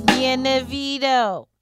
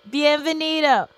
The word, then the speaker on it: Bienvenido: Bienvenido. That's not how you say it. Bienvenido. – Bienvenido.